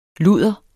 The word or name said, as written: ludder